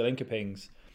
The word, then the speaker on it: Linköping: You know, I know how, I know how to pronounce it now. It's Linköpings. – Linköpings, excuse me. – I'm pretty sure, because I got Mia on Offside Raw Olympics podcast over the summer, and I think I heard her say it, and I was like, that's how you say it. But Mia, if you're listening, correct me if I'm wrong, but I'm pretty sure it's like Linköping.